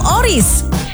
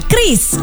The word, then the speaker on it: QRIS: Ada yang menyebut QRIS, QRIS, atau ORIS. Kepala Grup Departemen Kebijakan Sistem Pembayaran PKSPBI, Fitria Irmi Triswati mengungkapkan, pengucapan yang benar adalah QRIS.